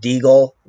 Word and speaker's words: Degle: It's Robert Deagle, BJJ. My last name is spelled D-E-G-L-E.